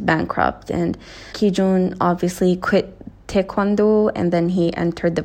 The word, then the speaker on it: taekwondo: Ki-jun-i was once a taekwondo player, okay? Or taekwondo, as we pronounce it in Korean.